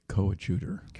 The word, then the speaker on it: coadjutor: the coadjutor. – Coadjutor. – Yes.